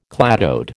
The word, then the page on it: cladode: American English